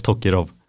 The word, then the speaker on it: Tokirov: Jag vet inte hur man uttalar hans förnamn, men jag vet att hans efternamn uttalas Tokirov.